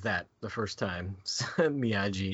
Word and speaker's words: Miyagi: Yeah, Miyagi. – So if you guys didn't know how it was said, would you have pronounced it that way, too? – Yeah, with a G-I at the end.